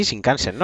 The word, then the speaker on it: Shinkansen: Eh, se dice así, Shinkansen, ¿no? sí, el, tren, tren, bala este.